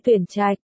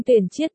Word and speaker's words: tuyển trạch: Rất nhiều chữ có âm đọc không đúng, nói thí dụ như tuyển trạch, chọn, anh luôn đọc là chiết,